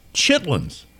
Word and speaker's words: chitterlings: that that's pronounced chitlins. Well, I've heard of chitlins all my life, especially on Beverly Hillbillies and shows like that. So, chitterlings is how it's spelled, but I just found out something with this call.